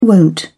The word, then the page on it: wont: British English